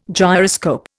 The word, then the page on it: gyroscope: Indian